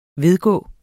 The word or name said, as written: vedgå